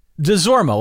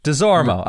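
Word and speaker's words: Desormeaux: DeZormo, like the jockey. – DeZormo.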